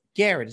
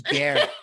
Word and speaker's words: Garrett: His name rhymes with ferret. – Garrett. His name's Garrett. Garrett.